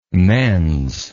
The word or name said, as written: mens